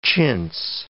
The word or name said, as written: chintz